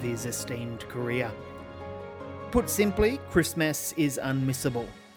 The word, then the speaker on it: Christmess: And if you're wondering why I'm pronouncing it Christmess, that's because that is the title of the film, and uh, that is also how it's spelt Christmess. So when you're looking for it, it's M E S S on the end instead of M A S.